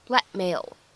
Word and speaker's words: blackmail: 再往后面看勒索这个单词 blackmail，它当中的话呢，本来就存在一个不完全爆破的处理，此时呢，我们这样一个爆破音只做了口型，并没有完全读出来，所以的话呢，这样一个单词咱们可以直接读成 blackmail。